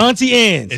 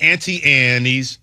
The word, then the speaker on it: Auntie Anne's: Auntie Anne's. – It's not Auntie Annie's, because Ant is an insect, Aunt is a person, and it's Ant. The E is silent.